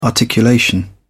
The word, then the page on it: articulation: British English